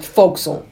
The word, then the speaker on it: forecastle: What I want to know is why is it spelt forecastle and pronounced fo'c'sle?